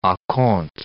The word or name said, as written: a compte